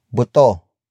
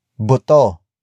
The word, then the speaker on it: buto: Up next we have buto. Buto. Buto. Buto is spelled as B-U-T-O.